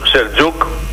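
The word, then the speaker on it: Selçuk: Λοιπόν, κάτσε να θυμηθώ, Σελτζούκ, Σελτζούκ, καλά το λες, καλά το λες. Με C είναι, αλλά προφέρεται Σελτζούκ.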